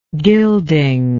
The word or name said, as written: gilding